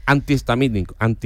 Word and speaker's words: antihistamínico: Y hay otra que también es así, digo que yo siempre la he dicho mal, que es antihistamínico. – Es verdad, sí. es cierto. – Lleva H intercalada entre las dos.